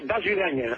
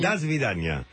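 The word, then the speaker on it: Das Vidaña (До свидания): Hasta pronto, Tlatidaña, hasta pronto. – ¿Cómo se dice? – Se dice Das Vidaña. – Das Vidaña.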